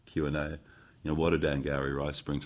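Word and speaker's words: Angourie: Angowri, like Maori.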